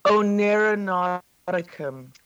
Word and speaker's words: Oneironauticum: Oneironauticum.